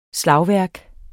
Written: slagværk

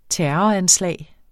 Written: terroranslag

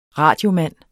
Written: radiomand

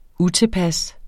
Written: utilpas

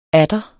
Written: atter

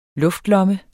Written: luftlomme

